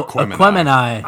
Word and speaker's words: Aquemini: Aquamini, am I pronouncing that correct? – No, Aquemini.